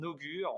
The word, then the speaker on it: Ogury: Après, le, on l'a un peu twisté au niveau de l'orthographe, ça s'écrit O-G-U-R-Y, parce que c'était pas libre tout simplement dans son écriture originale.